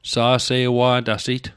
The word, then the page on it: sa'se'wa'tasit: Mi'gmaq